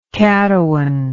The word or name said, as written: caddoan